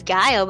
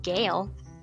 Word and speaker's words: Gayle: I thought it was Guile, but apparently it's Gale. – How do you spell it? – G-A-Y-L-E.